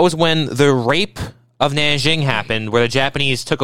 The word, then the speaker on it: Nanjing: I think this is pronounced Nanjing.